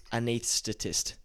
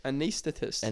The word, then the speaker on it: anaesthetist: Anaesthetist. – Anaesthetist. Anaesthetist. It's like you say the TH before the S, but the S is before the TH.